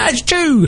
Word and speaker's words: Chu: Everyone's like, how do you say his name? It's S.Chu, like C-H-U. – So I've been saying Shu, Shu. – Shu, Shu. – Rather than Chu.